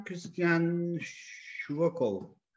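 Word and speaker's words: Christian Schwochow: Yönetmen Christian Shvokov diye okunuyor adı.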